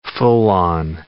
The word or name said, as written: full-on